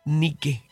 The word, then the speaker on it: Nike: Nike, acento en la E. Como Nike.